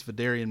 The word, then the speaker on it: Phidarian: Uh, Philadrian, Philadrius. Uh, I can't think how to pronounce his name. Pretty sure his last name is Mathis. – Fedarian. Um, I think it's Phidarian Mathis.